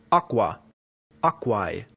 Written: aqua

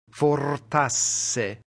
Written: fortasse

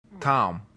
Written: tam

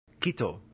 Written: cito

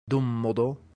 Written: dummodo

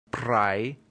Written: prae

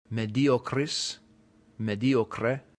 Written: mediocris

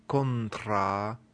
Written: contra

